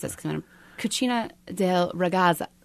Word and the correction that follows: Cucina della Ragazza: Cucina della Ragazza, which translates as Kitchen of the Young Girl.